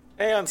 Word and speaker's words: Aeon: So specifically in StarCraft, there is the Aeon of Strife. I'm probably butchering that word. Aeon? – Aeon sounds Aeon. Right. – For a noob, Aeon sounds right. – Yeah, that sounds right.